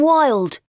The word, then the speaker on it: Wild: I'm Jim Wilde. – Jim Wilde. Do you spell that W-I-L-D? – Yes, that's right.